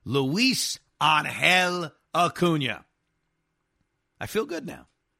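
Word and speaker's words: Ángel: It's not Angel like the team that lost Otani. It's pronounced Angel. Luis Angel Acuna, all the love.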